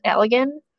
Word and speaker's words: Allegan: It's Allegan County, A L L E G A N.